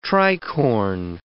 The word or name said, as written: tricorne